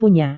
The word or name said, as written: punya